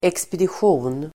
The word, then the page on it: exp: ekspedisj'o:n